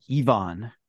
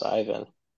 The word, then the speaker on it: Ivan: Oh, when I asked Ivan why, actually, Ayako pronounces his name Ivan. So maybe he even affected a, well, affect, I don't know. He pronounced his name Ivan, which is very continental now, isn't it? – Also not British though. British kind of say it like us, Ivan.